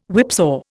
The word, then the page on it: whipsaw: Indian